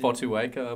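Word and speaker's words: Fotuaika: Well, how would you pronounce it? – Fotuweka, I believe. – Fotuweka. – The accepted pronunciation.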